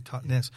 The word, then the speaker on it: Totnes: Yeah, it's the Totnes, as you say it properly, Totnes.